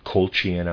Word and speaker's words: Colchian: Colchian, I'm pronouncing it C-O-L-C-H-I-A-N, Colchian.